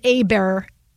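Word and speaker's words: Åberg: And matchups, I like Hovland and Eber. The G is silent.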